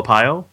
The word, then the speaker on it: Arpaio: Let's also, when you're talking about that Sheriff Joe, how do you say his last name? – Is it Sheriff Joe Apayo? – Okay, yeah, jackass.